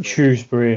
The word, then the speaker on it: Shrewsbury: Is it Shrewsbury or Shrewsbury? Shrewsbury, yeah. – Bit of a political one, that one, isn't it? I think the more posh types will call it Shrewsbury. – Yeah, the BBC, unfortunately, pronounce it wrong as well, which doesn't help.